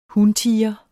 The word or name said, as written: huntiger